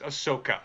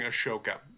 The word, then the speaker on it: Ahsoka: Uh, I'm I'm, I'm moving the H to the wrong place. Ahsoka. I'm, I'm, I'm pronouncing Ashoka. Ahsoka.